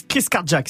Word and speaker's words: Carjack: Ouais, en fait, ça se prononce Chris Carjack. Si tu dis très vite avec l'accent du sud, bah ça donne Chris Cardiac.